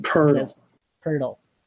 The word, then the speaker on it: Poeltl: No, there's no R in there. – Like a cat would do. – There's no R in there. – That's that. Hey, look at Basketball Reference's pronunciation. – Poeltl.